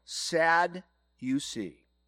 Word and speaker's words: Sadducees: Pronounce it this way, sad you see.